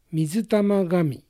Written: Mizutamagami

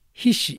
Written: Hishi

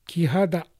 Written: Kihada

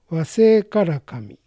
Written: Waseikarakami

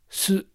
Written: Su

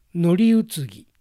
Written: Noriutsugi

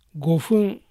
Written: Gofun

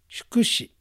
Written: Shukushi

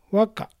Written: Waka

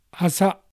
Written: Asa

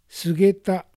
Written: Sugeta

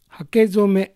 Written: Hakezome

